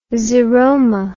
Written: xeroma

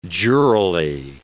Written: jurally

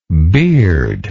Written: beardlike

